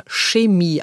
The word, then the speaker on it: Chemie: Bei Chemie scheiden sich ja die Geister, weil dieses Wort in einigen Dialekten wie Chemie oder Chemie ausgesprochen wird. Im Deutschen heißt es allerdings Chemie.